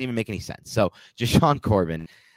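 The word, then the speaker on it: JaShaun Corbin: For me, it would actually be between Breida and Antonio Williams because I would be keeping Joshua Corbin or Joshuan Corbin. How do you pronounce his name? – Jashawn Corbin. – Jashawn. I don't know why I call him Joshuan. Jashawn Corbin.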